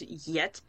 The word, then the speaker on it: Jet: Well, any time that they, like, I loved the entire, everything revolving around, like, Jet and her father and the Aramites, that whole story quest from... – Apparently, also, this is going to be really awkward, but apparently it's supposed to be pronounced Yet.